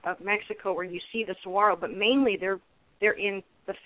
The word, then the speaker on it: saguaro: Like some, if you hadn't, some, I hear people saying saguaro, and because it has the G, but it's pronounced saguaro.